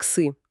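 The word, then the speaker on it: сы (四): В китайском языке иероглиф 4 читается как «сы». И точно так же, отличаясь лишь тональностью, звучит слово «смерть».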